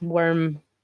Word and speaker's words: wyrm: It's worm, as in worm with a Y, so W... Y-R-M, yeah.